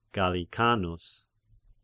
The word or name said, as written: Gallicanus